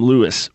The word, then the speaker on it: Louis: Look, if someone thought that Michael Brown, here, Michael Brown's a very common name, even when you add in my middle name, Lewis, which is spelled L-O-U-I-S, there's still a lot of Michael Lewis Browns out there.